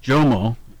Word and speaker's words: Jomo: I think it's Jomo, J-O-M-O, Kenyatta.